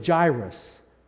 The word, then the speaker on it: Jairus: Jairus. Can you say Jairus? Kids, can you say Jairus? Jairus. Adults, can you say Jairus? Yeah, Jairus. J-A-I-R-U-S is the way it's spelled in the English version. So we'll pronounce it that way.